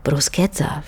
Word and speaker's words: bruschetta: Und ehe man zudem solche Sachen wie das mit dem C und dem H, das wie ein K ausgesprochen wird im Italienischen, wie eben in Crucchi oder Bruschetta, verstanden hat, kann es ja auch mal ein paar graue Haare lang dauern.